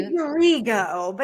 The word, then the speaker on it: Rigo: Rigo, but that's okay.